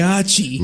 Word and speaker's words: Heihachi: Heihachi.